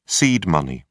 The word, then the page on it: seed money: British English